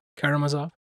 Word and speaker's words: Karamazov: but I'm reading The Brothers Karamazov. Looking to get pronunciation. – Karamazov.